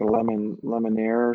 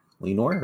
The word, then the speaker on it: Lenoir: It's like Lemonair or something. – Lenoir, right? – Okay, yeah.